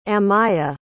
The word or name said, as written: amayah